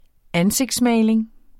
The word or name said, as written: ansigtsmaling